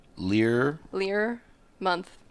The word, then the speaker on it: Learmonth: Learmonth. Oh, like a year, like month and a year.